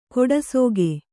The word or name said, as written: ಕೊಡಸೋಗೆ